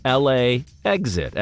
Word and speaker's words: LAX-IT: It is spelled LAX-IT. It's pronounced LA exit, as in fly into the airport and then make an attempt to exit the airport.